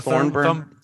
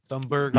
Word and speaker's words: Thunberg: Greta Thornburn. Thunberg.